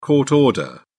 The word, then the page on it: court order: British English